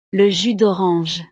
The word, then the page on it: le jus dorange: frances